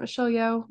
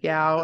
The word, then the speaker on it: Michelle Yeoh: Is that how you pronounce it? Michelle Yeoh? – Yes, Michelle Yeoh. – Michelle Yeoh. – Now we know. – You're going to have to edit and go back on me because I said Yao That's and then I said you.